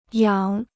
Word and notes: 仰: yǎng

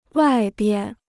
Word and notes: 外边: wài bian

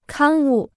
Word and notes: 刊物: kān wù

